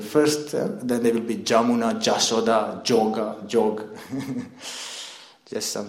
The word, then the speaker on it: Jamuna: And mostly the ypsilon, the y, is pronounced the j. Like Jamuna, they will not say Yamuna, they will say Jamuna.